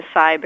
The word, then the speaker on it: acai: I never pronounce this right, but the acai berry that looks like a kai that comes from Brazil, that's supposed to stop the aging process, filled with antioxidants that will make us go on forever and be younger? – Right, the acai berry, that's how you say it.